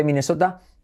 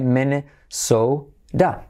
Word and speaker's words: Minnesota: ¿Cómo se dice Minnesota? Minnesota. Mene. Y como E, fíjate que la I suena igual que la E. Minnesota. T como R. Suave.